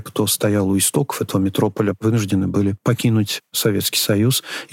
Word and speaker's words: Метрополя: Действительно, Владимир Высоцкий для «Метрополя», именно так принято ставить ударение, подобрал несколько текстов песен, которые представил в виде стихов.